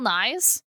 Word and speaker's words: Nighy: There's two Bill Nyes? – There's two Bill Nyes. The Englishman spells his name N-I-G-H-Y, but it's pronounced Nye.